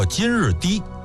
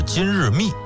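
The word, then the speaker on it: 金日磾: 武帝看上的第二位顾命大臣呢是匈奴籍的，叫金日低。在这儿啊我们稍微再多说一句啊，就是我看有的纪录片里边呢把这个金日低读成是金日密，我查了相关的这个资料没找到这个密字的发音的出处，所以咱们还是说成是金日低吧。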